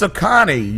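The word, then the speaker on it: Oconee: Ukun. – Come find Ukun Brandon and kill him. – County, Georgia. Wednesday after he claimed his dog... – Oh, no. It's Akani. You fucking said it wrong.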